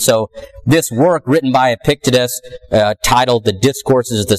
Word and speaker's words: Epictetus: Also, the first century philosopher Epictetus, again, struggling to pronounce these names, Epictetus.